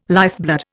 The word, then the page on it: lifeblood: Indian